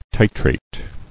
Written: Titrated

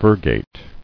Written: Virgate

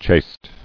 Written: Chaste

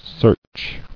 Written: Searcher

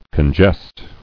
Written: Congest

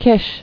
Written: Kish

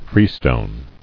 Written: Freestone

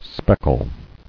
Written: Speckling

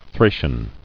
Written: Thracian

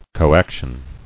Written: Coactively

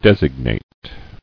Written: Designator

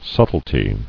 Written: Subtlety

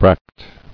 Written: Bracteal

